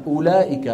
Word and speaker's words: أولئك: صفر مدور، على أن هذه الواو لا تنطق أبدا. يعني ما حدا بيقول أولئك على هدى من ربهم وأولئك، ما حدا يفعل ذلك.